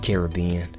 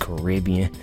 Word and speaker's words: Caribbean: I was like, that's, in fact, I think that's how they pronounced it in the trailer at best. That's how I pronounced it. So, I mean, but it's actually pronounced Caribbean.